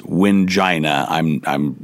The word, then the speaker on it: Wingina: Yes, Wingina. That's how you pronounce it. I'm not kidding.